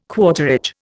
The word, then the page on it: quarterage: Indian